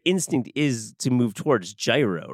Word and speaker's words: gyro: I love that you pronounce it gyro, not gyro, even though you're from Long Island.